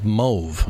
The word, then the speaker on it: mauve: Professor goes, you have to know the origin of the word to know how to pronounce it. – What? – M-A-U is French. Mm-hmm. Is in chauffeur. A-U in French is an O sound. It used to be the official pronunciation of that word was mauve. – Mauve. – And nobody ever says mauve, so now they've added mauve as a approved pronunciation of that word.